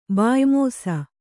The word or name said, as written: ಬಾಯ್ಮೋಸ